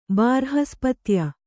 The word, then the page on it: ಬಾರ್ಹಸ್ಪತ್ಯ: bārhaspatya